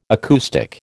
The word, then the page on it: acoustic: American English